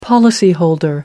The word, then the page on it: policyholder: American English